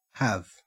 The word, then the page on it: have: Australian English